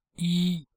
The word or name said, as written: yi1